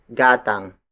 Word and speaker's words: gatang: The most basic native instrument to measure volume is gatang. Gatang. Gatang. Gatang is spelled as G-A-T-A-N-G.